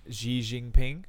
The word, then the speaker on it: Xi Jinping: Like the new Chinese president, Xi Jinping, I think is how you say his name.